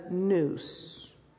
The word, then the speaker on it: nous: You pronounce it in the Greek as a nous.